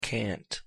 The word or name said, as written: cant